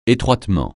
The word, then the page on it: étroitement: français